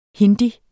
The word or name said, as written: hindi